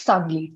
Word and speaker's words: Sangli: And you do this by going to look at the way that agriculture has changed or not changed in a village called Sangli. Is that correct? Am I pronouncing it correctly? – Right. That's an alias, but yes, Sangli.